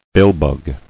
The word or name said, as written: Billbug